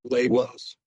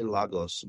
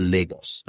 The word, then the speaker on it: Lagos: And by the way, am I pronouncing Lagos correctly? – Lagos. Well, the Portuguese will say Lagos, but it's anglicized since we're English speaking, so we say Lagos.